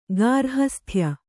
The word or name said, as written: ಗಾರ್ಹಸ್ಥ್ಯ